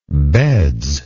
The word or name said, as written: beds